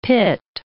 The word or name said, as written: pit